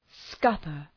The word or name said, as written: scupper